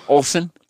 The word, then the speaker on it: Olson: Olson. – Joe Olson. – O-L-S-O-N. – Like the Olson twins? – No, that's S-E-N.